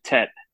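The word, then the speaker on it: Tete: No accent, Tett. – Yeah, the worst. – Like the French pronunciation. Tett.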